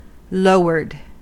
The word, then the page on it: lowered: American English